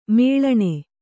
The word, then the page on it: ಮೇಳಣೆ: Kannada